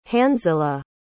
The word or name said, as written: hanzila